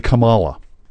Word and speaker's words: Kamala: It's actually Kamala.